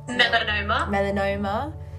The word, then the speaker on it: melanoma: You have like, is it melanoma? Is that how you pronounce it? – Melanoma.